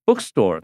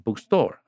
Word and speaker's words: bookstore: 啊，come 就是来，to 就是介系词到这里来啊，到这个地方，这个地方什么地方呢？This bookstore often 啊，bookstore 通常它的重音也是在前面啊，不要把它念成 bookstore 啊，bookstore。